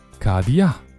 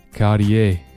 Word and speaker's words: Cartier: Cartier Cartier